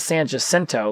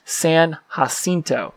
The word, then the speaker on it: San Jacinto: Well, it's not Jacinto. It's not San Jacinto like we would normally pronounce it. No, it's pronouncing it correctly. It's San Jacinto, like Cinco, but Cinto.